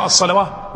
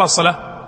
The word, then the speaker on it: الصلاة: منا يقرأ الصلواه ولا يقرأ الصلاة ويعرف أن هذه الواو إيش زائدة ولا لا؟ يعني ليست من أصل يعني ليس إنما هي إشارة إلى أصل كلمة، أصل الألف، وليست ولا تنطق، يعني تكتب ولا تنطق، وينطق المحذوف بدلا منها اللي هو الألف.